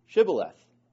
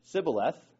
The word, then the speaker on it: Shibboleth: And if they said no, they would say to him, "Say now, Shibboleth." And he said, "Sibboleth," for he could not pronounce it correctly.